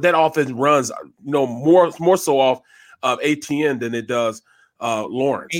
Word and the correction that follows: Etienne: No, it's Etienne, man. – Etienne, yeah.